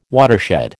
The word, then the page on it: watershed: American English